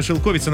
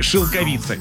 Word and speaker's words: шелковица: Далее у нас была шелковица, но это неправильно. Словарь говорит, что все-таки шелковица. Непривычно, согласны, но что ж поделаешь.